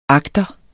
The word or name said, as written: agter